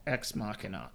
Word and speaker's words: Ex Machina: But I didn't fall in love with him until I saw Ex Machina.